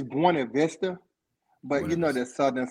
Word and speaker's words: Buena Vista: Is that in Buena Vista? – Buena Vista, correct. – Yes. Buena Vista, that's how you say it.